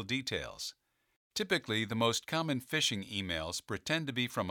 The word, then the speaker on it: phishing: P-H-I-S-H-I-N-G, pronounced phishing,